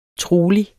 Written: trolig

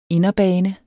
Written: inderbane

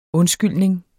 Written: undskyldning

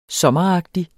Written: sommeragtig

